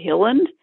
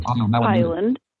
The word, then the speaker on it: Hiland: But also, she contacted Leonore Dvorkin to ask her some questions about how to pronounce my name, for instance, because my name is spelled H-I-L-A-N-D, and so many people say Hilland, which is just so wrong because there are not two L's in it. It's one L, so it's Highland.